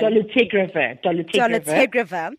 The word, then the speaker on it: dolutegravir: And more recently, the Dolutegravir, how do we pronounce that one? – Dolutegravir has been said to be introduced.